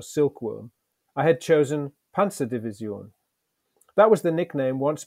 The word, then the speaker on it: Panzerdivision: It should be pronounced as nasally as possible, with a soft S and the stress on the final syllable, Panzerdivision.